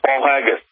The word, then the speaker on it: Paul Haggis: It has been hailed by two Academy Award winner Paul Higgins as powerful and often chilling eye-opener. I'm pronouncing that Paul Haggis. I think I'm pronouncing that right? – It is. It's Paul Haggis.